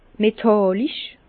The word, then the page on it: metàllisch: Bas-Rhin; Herrlisheim